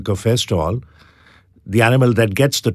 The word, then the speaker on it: cafestol: K-A-H-W-E-O-L, caviol. And then there's something called cafestol, C-A-F-E-S-T-O-L.